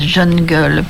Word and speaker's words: jungle: la jungle, faut paraît-il prononcer à l'anglaise, jeune gueule.